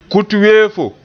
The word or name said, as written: kut-weefo